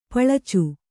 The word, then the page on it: ಪಳಚು: paḷacu